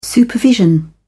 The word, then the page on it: supervision: British English